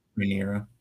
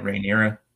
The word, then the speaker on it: Rhaenyra: Is that how you say her name? – Rhaenyra. – The princess is? – Yeah, Rhaenyra.